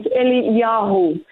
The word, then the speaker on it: Eliyahu: Elijah in Hebrew, the way that you really pronounce his name is Eliyahu.